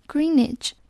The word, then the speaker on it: Greenwich: Greenwich 要注意它的发音，W 是不发音的，所以读作 Greenwich。